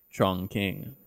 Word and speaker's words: Chongqing: So we did go to a Chinese place, Impression Chongqing.